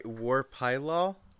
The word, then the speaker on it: Pylol: Sorry, War Pylol? – Yes.